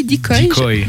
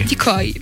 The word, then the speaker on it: Decoy: Et la troisième catégorie, alors je sais pas comment on prononce, ça s'appelle ça s'écrit D E C O Y, donc c'est l'APA Dicoy Dicoy Dicoy.